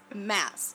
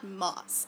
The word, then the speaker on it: Maas: Yeah, but guys, she pronounces her own name wrong. It's Mass, not Moss. And she says it's Moss, right? – No, she says it's Mass. – No. – She says Mass like Massachusetts.